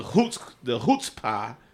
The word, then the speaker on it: chutzpah: It's spelled C-H-U-T-Z-P-A-H. The C is silent.